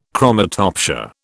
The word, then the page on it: chromatopsia: British English